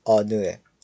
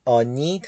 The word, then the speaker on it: 어느: خب کلمه بعدی ما کلمه آنوه. آنو به معنی کدامه. دقت کنید که این کلمه آنی تلفظ نمیشه، بلکه آنو تلفظ میشه.